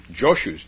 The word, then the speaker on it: Joshu: and it's Zhao Shou's dog, Joshu's dog, depending on whether you use the Chinese or the Japanese pronunciation.